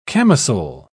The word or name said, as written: camisole